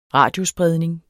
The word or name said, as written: radiospredning